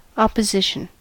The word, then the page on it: opposition: American English